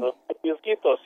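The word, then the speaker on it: Los Peñasquitos: I was wondering, what's a microclimate that you'd find in Los Pianosquitos? Did I even come close to pronouncing that correctly? – Los Pianosquitos, you did.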